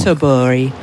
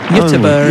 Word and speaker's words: Göteborg: Well, in English, the version is Gothenburg. But Göteborg, obviously, is how you say it in Swedish, but I gather that that's not the correct way to pronounce it. – No, it is not.